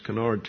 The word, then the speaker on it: Kennard: They live in Kennard, I think it's pronounced, Kennard, Texas.